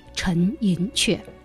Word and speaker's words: 陈寅恪: 那学术圈呢，更习惯于以客家人发音，而称他为陈寅恪。